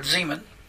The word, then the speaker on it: zeman: And it means, it's the word zeman. And Z-E-M-A-N is the way you would translate it or pronounce it.